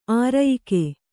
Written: ಆರ್ಯಕೆ

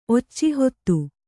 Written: ಒಚ್ಚಿಹೊತ್ತು

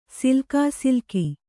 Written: ಸಿಲ್ಕಾಸಿಲ್ಕಿ

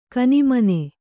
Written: ಕನಿಮನೆ

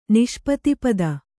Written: ನಿಷ್ಪತಿಪದ

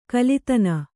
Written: ಕುಲ್ಲತನ

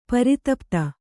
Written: ಪ್ರತಿಪತ್ತು

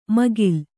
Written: ಮೇಖಲೆ

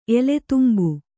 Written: ಎಲೆತುಂಬು

